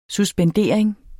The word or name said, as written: suspendering